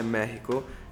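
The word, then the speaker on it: México: Il Messico, la cui pronuncia reale è Mexico,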